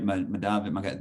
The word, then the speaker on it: Mugabe: He just told jokes about like smoking weed and like Mugabe and am I saying that right? Mugabe, there it is.